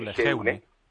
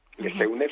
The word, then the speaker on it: Lejeune: Escrito Lejeune. – Lejeune, efectivamente. Mi pronunciación en francés tampoco es muy, muy buena, exactamente, y cuestan 10 euros.